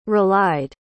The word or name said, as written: relied